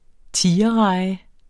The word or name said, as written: tigerreje